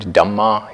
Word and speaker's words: Dhamma: That's probably the most liberal, the most broad way of understanding the word Dhamma here with a long A, meaning not the Dhamma of the Buddha, but the many Dhammas that constitute our experience.